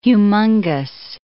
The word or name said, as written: humungous